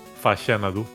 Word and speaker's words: Faxanadu: ¿Cómo cojones se pronuncia esto realmente? Porque... – Faxanadu.